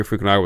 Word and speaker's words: Fukunaga: Cary Fukunawa? – Sure. – And his writing partner... Cary Fukunaga was going to direct this movie for years, and his version was way different.